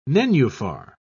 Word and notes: nenuphar: British English

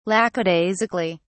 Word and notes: lackadaisically: British English

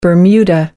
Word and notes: bermuda shorts: American English

